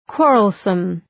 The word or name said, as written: quarrelsome